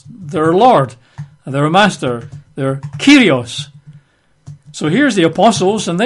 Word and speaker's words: kurios: They use the Greek word kurios, K-U-R-I-O-S, or pronounced kurios, I believe.